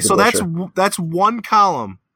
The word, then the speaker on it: DeBusschere: Dave DeBusschere.